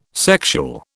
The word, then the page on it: sexual: British English